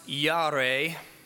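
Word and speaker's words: yare (יָרֵא): In Hebrew, the word fear, it's pronounced yare. That's as best as it's going to get from me.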